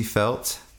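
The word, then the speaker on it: Feldt: She is Casey Felt, and that's F-E-L-D-T. The D is silent. It's a German name. – Casey is C-A-S-E-Y.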